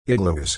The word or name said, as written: igloos